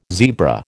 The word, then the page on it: zebra: American English